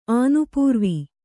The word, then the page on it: ಆನುಪೂರ್ವಿ: ānupūrvi